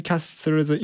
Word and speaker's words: Castle: さらにその L の発音、 Castle の L の発音っていうのはこれ日本語のルのような発音ではないんですよ。ウとかオのような発音に近い、まあ、あるいは全く聞こえてないかなっていうふうに発音になっているんですよね。